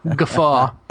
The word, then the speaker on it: GUFW: And I think it's kind of funny because now there's a GUI, the GUFW, which I dub the official Fresh Ubuntu pronunciation to be guffaw.